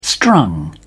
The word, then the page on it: strung: British English